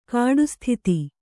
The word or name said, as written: ಕಾಡುಸ್ಥಿತಿ